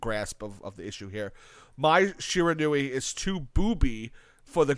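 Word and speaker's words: Mai Shiranui: My Shiranui. – My Shiranui.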